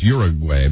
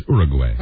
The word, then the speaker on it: Uruguay: Yeah, when we all went to school, they told us Uruguay, but right. Really the correct pronunciation is Uruguay.